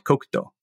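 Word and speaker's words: kokuto (黒糖): And the sugar is called kokuto.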